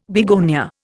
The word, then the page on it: begonia: Indian